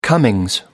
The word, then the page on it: comings: American English